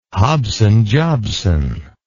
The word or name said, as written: hobson jobson